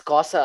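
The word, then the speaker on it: Cosa Nostra: It's Cosa. I don't know why it's written. – Say it correctly, my guy. – No, but it's written Costa on the dock. – Bro, it's confusing me, okay? – It's actually Cosa, but in the dock it says Costa.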